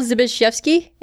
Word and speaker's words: Zbyszewski: This episode was written by Paul Zbyszewski, and Lauren told me that's the right way to pronounce it.